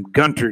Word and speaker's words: Gunter: Let me make sure I pronounce his name right. He is brand new. Defensive lineman Rodney Gunter. That's how you pronounce his name, Gunter, G-U-N-T-E-R.